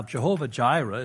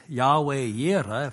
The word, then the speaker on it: Yahweh Yireh: Now, Jehovah Jireh is nowhere close to how it would sound if someone said it in Hebrew. It would be Yahweh Yireh if they pronounced it.